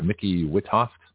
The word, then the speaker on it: Micki Witthoeft: So first of all, congratulations to you for our guest, and I hope I pronounced it right, Micki Witthoeft. Did I pronounce that correctly? – Micki, yes.